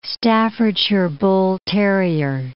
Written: Staffordshire bull terrier

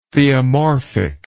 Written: theomorphic

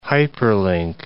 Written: hyperlink

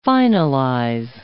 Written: finalize

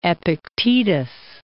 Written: Epictetus

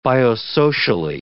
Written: biosocially

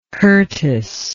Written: curtiss

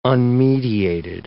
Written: unmediated

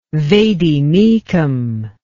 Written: vademecum

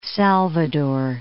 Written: Salvador

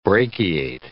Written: brachiate

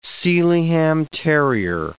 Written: Sealyham terrier

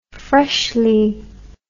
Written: freshly

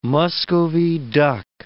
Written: Muscovy duck

